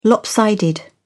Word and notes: lopsided: British English

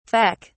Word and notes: feck: British English

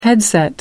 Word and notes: headset: American English

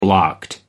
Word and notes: blocked: American English

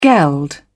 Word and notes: geld: British English

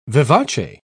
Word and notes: vivace: British English

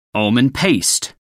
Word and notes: almond paste: American English

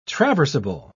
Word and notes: traversable: British English

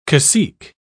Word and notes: cacique: British English

